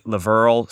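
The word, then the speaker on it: LaVyrle: Morning Glory by Laverl Spencer. – Mm-hmm. – And I pronounced that correctly because one of the troopers, Katina, let me know that the second syllable rhymes with Merle.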